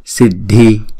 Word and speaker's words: Siddhi: Vijaye, Siddhi, Siddhi, Siddhi, Siddhi. Chữ D và chữ H ghép lại thì chúng ta đọc là dh, dh, Siddhi, Siddharthe.